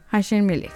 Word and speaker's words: Hashem Melech: La canción se titula así, Hashem Melech. ¿Sí? ¿Está bien? ¿Lo dije bien? Sí, sí. Hashem Melech.